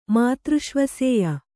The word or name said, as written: ಮಾತೃಷ್ವಸೇಯ